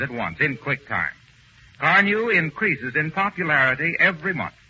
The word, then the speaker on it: Carnu: But don't forget to buy that package of Johnson's Car New this week. It's spelled C-A-R-N-U.